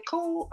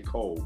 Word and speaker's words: Coel: Like, when you tell me that Michaela Cole, is it Cole or Coel? Anyway. – I say Cole, but I think it is spelled like Coel, but.